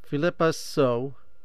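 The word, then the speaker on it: Phillipa Soo: My daughter was trying to teach me how to say this name. Philippa So.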